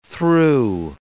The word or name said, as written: thro